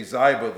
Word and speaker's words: Ziba: Now I've always heard Ziba, but my self-pronouncing Bible says it's a long I. So I guess I'm going to say Ziba this morning.